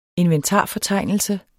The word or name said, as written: inventarfortegnelse